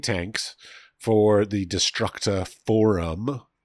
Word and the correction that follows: Destructura: correct? Is that phrased correctly? – Destructura. – Okay. I'm sure I mispronounced that, but... Destructura. – There we go.